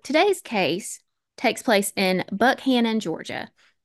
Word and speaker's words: Buchanan: Now, off the rip, I need to clarify with y'all that despite being spelled like it would be pronounced Buchanan, it is in fact Buckhannon. And Rob, if you hear me slip and say Buchanan, please call me out so I don't sound dumb. It is Buckhannon.